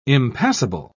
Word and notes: impassible: British English

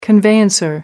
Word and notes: conveyancer: American English

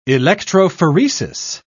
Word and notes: electrophoresis: British English

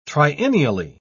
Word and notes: triennially: British English

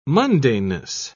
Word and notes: mundaneness: British English

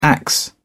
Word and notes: axe: British English

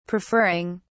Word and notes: preferring: British English

